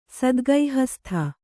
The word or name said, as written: ಸದ್ಗೈಹಸ್ಥ